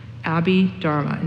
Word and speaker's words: Abhidharma: And then the last set of teachings he gave was called the Abhidharma. That's spelled A-B-H-I-D-H-A-R-M-I. Abhidharma. And that first H may not be in there, so my spelling may be off.